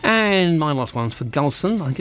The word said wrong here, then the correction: Gilson: Gilson is pronounced that way.